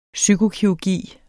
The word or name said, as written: psyko-